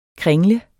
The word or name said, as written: kringle